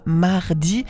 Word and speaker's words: Maredi: Et nous étions avec la nouveauté zurichoise du groupe zurichois Mardi. Je le prononce comme ça parce que ça s'écrit M-A-R.E-D-I.